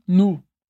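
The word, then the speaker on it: nous: E aqui eu aproveito para lembrar que tanto em nu quanto em vous, a gente tem um S no final da palavra. Mas, seguindo a regra geral de pronúncia que eu te expliquei na aula, esse S não é pronunciado. Por isso, a gente tem nu e vu.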